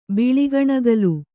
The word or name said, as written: ಬಿಳಿಕಣಗಲು